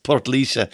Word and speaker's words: Portlaoise: Hi, Oliver, you can call it Maryborough if you want, but it's pronounced Portlaoise with the E at the end, says this texter who remembers my last time being in here, which I've already forgotten, Maryborough.